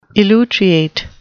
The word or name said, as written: elutriate